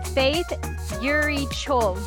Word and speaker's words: Faith Eury Cho: Revelation Wellness community, I'm super excited to be sitting here today with Faith Eury Cho.